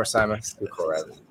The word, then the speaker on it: Szuharev: Szuharev. With the Russian accent, it's Szuharev. But I don't expect y'all to say that.